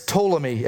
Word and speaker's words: Ptolemy: We see here, then the king of the south is Ptolemy. Everybody say Ptolemy. If you want to look him up, it starts with a P, all right? Silent P, so be careful if you are going to look these guys up.